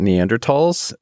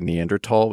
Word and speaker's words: Neanderthal: Dana and I are going to say Neanderthal, which is the correct pronunciation Germanic pronunciation. I tried to blur it just now. – And you're saying the sort of debased, Americanized version, Neanderthal, just so we're clear on that.